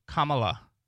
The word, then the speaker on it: Kamala: It's pronounced Kamala. Like comma and then la.